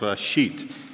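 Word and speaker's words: Rashit (בראשית): A lot of people don't know, but the word Genesis comes from the Hebrew word, which is pronounced Rashit, and it literally means in the beginning.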